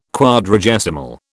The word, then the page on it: Quadragesimal: British English